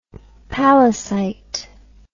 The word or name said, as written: pallasite